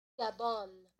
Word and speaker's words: Gabon: You know, I don't know if that country is pronounced that way. Let me play this from Google search right now. – Gabon. – Gabon is how you pronounce it.